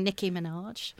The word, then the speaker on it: Nicki Minaj: But there was quite a lot of lovely women, sort of Little Mix in there, and of course featuring Nicki Minaj.